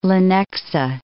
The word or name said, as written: Lenexa